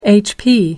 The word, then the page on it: hp: American English